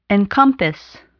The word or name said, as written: encompass